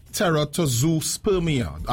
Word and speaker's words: teratozoospermia: He says, I have teratozoospermia. Am I pronouncing that correct? – Oh, you are really good. That's exactly how we say it, man.